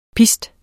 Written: pist

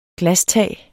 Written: glastag

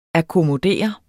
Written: akkommodere

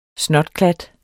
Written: snotklat

